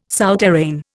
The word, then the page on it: souterrain: Indian